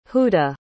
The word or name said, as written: huda